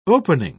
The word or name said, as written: opening